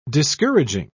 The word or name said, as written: discouraging